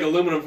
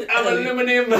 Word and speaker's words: aluminium: I was watching Community yesterday, and he's like, everything's going to taste like aluminum for a while, and he's like, it's pronounced aluminium.